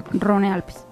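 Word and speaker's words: Rhône-Alpes: Eso es Averno Rino, Rino Alpes. – Rone Alpes. – O Rone Alpes, sí.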